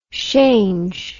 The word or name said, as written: shange